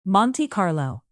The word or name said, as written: monte carlo